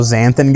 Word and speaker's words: xanthan: Xanthan gum. – Oh, xanthan gum? – Xanthan gum.